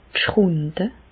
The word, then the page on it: d' Schrùnde: Alsatian; Bas-Rhin; Reichshoffen